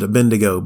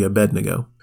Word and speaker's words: Abednego: A lot of people pronounce it Abednego, but it's actually Abednego.